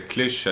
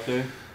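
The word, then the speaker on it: Cluj: När ni, vad blev det, vad heter det, Klysch, eller vad de uttalar det, Klöj. – Ja, Klöj.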